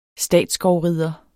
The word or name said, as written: statsskovrider